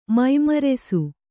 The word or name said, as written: ಮೈಮರೆಸು